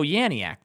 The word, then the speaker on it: Janiak: And, oh, Janiak, that's how you say her name, I think.